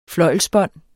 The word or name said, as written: fløjlsbånd